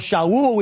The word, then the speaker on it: Shaul: It's just that he had a Hebrew name, Saul or Shaul, we would pronounce it in Hebrew, and he had a Greek name, Paul.